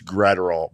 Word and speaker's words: Graterol: It was Evan Phillips, Alex Vesia, Brustar Graterol, and Craig Kimbrell, right? Those are the 4 relievers that the Dodgers used in this game. – It's Graterol, Bob Brenly. – Yes. I think you said Graterol.